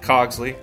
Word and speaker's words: Cogsley: It is pronounced Cogsley. C as in Charlie, O as in Octagon, G as in glad to meet you, S as in stupid adventurer, L as in lich, Y as in why.